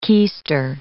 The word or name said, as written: keester